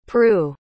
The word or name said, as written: prue